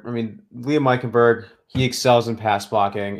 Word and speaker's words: Eichenberg: Continuing on here, we have our next offensive tackle here in Liam Eichenberg. Is that I say that right? – Eichenberg, yeah. – Eichenberg out of Notre Dame, 6'6", 305.